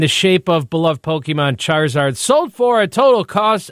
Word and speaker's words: Charizard: Charizard has sold at auction for a total of, Lisa, any guesses? – $10,000.